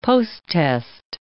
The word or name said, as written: posttest